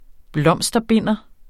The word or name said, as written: blomsterbinder